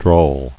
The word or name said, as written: Drawled